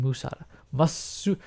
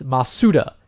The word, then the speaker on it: Masuda: I keep saying Masuda. I apologize. It's Masuda. Masuda. I keep saying his name wrong.